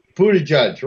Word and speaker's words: Buttigieg: But Joe Biden, Cory Booker, Pete Buttigieg, is that the way you pronounce his name? – Buttigieg, right.